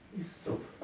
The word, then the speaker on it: Иссоп: Иссоп. На первой гласной ставлю значок прикрепления, да? Здесь читается как И. Иссоп.